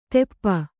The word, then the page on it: ತೆಪ್ಪ: teppa